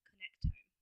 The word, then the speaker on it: connectome: And the, sorry, connectome? – Connectome. – How do you spell that? – Connect, O-M-E.